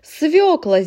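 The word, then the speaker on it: свёкла: Свекла, здесь буква «ё», на которую падает ударение в слове.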